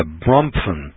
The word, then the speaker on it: Bronfman: which is owned and controlled by the Bromfman family. That's spelled B-R-O-N-F-M-A-N, Bromfman. Bromfman family.